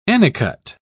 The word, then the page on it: anicut: British English